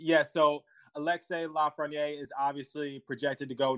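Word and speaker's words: Lafrenière: Alexis Lafreniere. I hope I got his name right, but Lafreniere. Alexei Lafreniere. – Lots of silent letters. Yeah, Quebec native, I think. Lots of silent letters in there.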